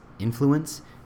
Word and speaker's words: influence: Take what is and slowly, subtly shape it using influence. I guess I can't speak of it. Influence, right? There's the right enunciation on the first syllable.